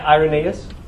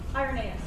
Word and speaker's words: Irenaeus: So, Irenaeus, am I saying that right? – Irenaeus. – Irenaeus. – Yeah.